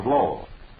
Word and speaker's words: Lowell: Now, take, for instance, your great city of Lowell. Think now what it would sound like if it were pronounced with the L silent. The result would be O.